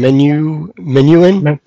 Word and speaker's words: Menuhin: So Gerard, I'm going to butcher his name. Menuhin, is that it? – Yeah.